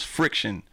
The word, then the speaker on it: Phrikshun: Oh, and then my original DJ name was Friction. Mm-hmm. And it was spelled like P-H-R-I-K-S-H-U-N, mm-hmm, right?